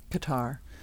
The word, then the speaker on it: Qatar: Qatar, was the podium finished by 40-year-old double world champion Fernando Alonso.